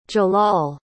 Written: jalaal

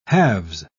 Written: halves

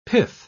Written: pith

